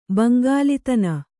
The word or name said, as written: ಬಂಗಾಲಿತನ